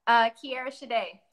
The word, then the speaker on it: Kierra Shiday: Kierra Shiday.